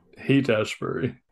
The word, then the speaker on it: Haight-Ashbury: the Audrey Habsburg. What? How do you say it? Where the clinic? – Haight-Ashbury. – Haight-Ashbury, yeah. Haight-Ashbury.